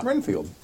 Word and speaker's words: Renfield: I'm playing Renfeld. Renfeld. Field. You spell it Field. Renfeld. – It is Field. – Renfield.